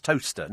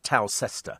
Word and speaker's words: Towcester: See, I know it's pronounced Toaster, not Towcester.